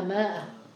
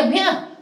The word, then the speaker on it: مئة: في القرآن الكثير يقرؤونها ماء وهذا خطأ. جيد تقرأ هي مئة ولكن زادوا الألفة لأنه لم يكن هناك تنقيط للغة.